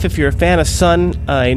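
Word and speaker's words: Sunn O))): Some people know them as Sun O, but actually, they're just pronounced Sun, even though their name is spelled S-U-N-N, and then there's an O, or a zero, and then three end brackets.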